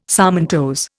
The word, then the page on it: sarmentose: Indian